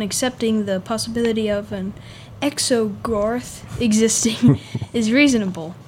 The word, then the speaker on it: Exogorth: actually a space slug called the Exogirth. Is that how you pronounce it? – Exogirth? – Exogirth? – It's Gorth? Like Gorth, I guess? – Exogorth.